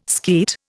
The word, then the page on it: skeet: Indian